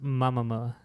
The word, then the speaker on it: Momoa: It's pronounced Mamamoa. – Mamamoa.